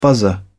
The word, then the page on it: buzzer: British English